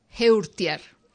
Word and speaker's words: Heurtier: Bueno, estamos a falar de Sweet Sixteen, de Annelise Heurtier. Francesa. – Non vedes o xeito, pero está como poñéndolle comillas a esta pronunciación. – Sí. En francesa, o apelido é Heurtier.